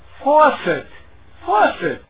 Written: faucet

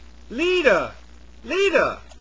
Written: liter

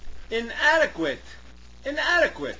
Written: inadequate

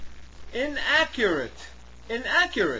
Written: inaccurate

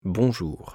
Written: bonjour

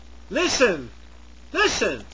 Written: listen

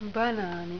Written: banaani

